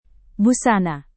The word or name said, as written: busana